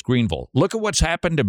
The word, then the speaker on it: Greenville: That's right, Greenville, which that's how you pronounce it. I know it has an I, but it's an ul.